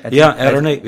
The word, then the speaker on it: Ederney: Ederney.